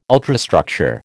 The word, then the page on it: ultrastructure: American English